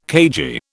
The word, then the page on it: cagey: British English